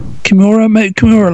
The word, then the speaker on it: Kimura: Oh, by the way, it's called the Kumara Lock. – Kumar. That's not how you pronounce it, though, is it? – Kimura? – Kimura Lock, yeah.